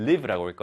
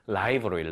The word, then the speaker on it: live: 그래서 정리를 하자면 동사, 살다라는 의미의 동사일 때는 live라고 읽고요. 형용사의 의미, 살아있는 혹은 생방송의 실시간의 의미일 때는 live로 읽는다는 거 꼭 기억하시고 이제 헷갈리지 말고 정확하게 구분해서 읽고 의미 파악하시기 바랍니다.